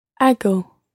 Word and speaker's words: hago: Hago is spelt H-A-G-O. Hago. – Hago. – And your Spanish G sound is quite a soft G there. Hago. – Hago. – Muy bien. It's not hago, but hago.